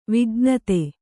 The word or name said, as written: ವಿಜ್ಞತೆ